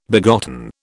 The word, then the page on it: begotten: British English